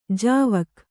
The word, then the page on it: ಜಾವಕ್: Kannada